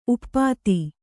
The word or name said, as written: ಉಪಾಧಿ